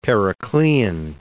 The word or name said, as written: Periclean